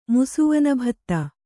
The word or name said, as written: ಮುಸುವನಭತ್ತ